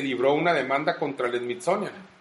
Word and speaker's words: Smithsonian: Smithsonian, sí.